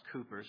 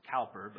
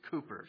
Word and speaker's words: Cowper: By the way, it is pronounced Cowper. Some people want to pronounce it Cowper, but it is Cowper.